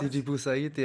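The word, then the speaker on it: Sidi Bouzid: wie spricht man das aus? – Sidi Bouzaïd, ja, genau.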